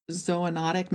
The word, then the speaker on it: zoonotic: Well, your book points out that emerging infectious diseases since 1940, 60% of them are what is known as zoonotic.